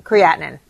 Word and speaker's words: creatinine: creatine, am I saying it right, babe? – Creatinine in my blood.